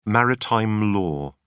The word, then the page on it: maritime law: British English